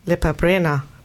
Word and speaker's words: Lepa Brena: Nämlich Lepa Brena.